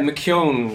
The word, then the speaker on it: McKeown: Yeah, McKeown would be my Irish pronunciation.